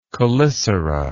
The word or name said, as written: chelicerate